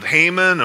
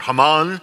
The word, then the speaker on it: Haman: Now, in this case, it's a genocidal law that has been passed by this creep by the name of Haman or Haman, depending on how you want to pronounce his name.